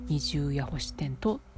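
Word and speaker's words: 二十八星天道: 漢数字の 28 に空の星とあと天の道と書いて二重ヤホシテントウという虫の名前らしいんですね。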